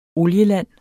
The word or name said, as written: olieland